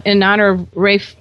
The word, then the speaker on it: Ralph: Now, there was a special dog in your life, and his name is spelled Ralph, but you pronounce it differently. – It's pronounced Rafe.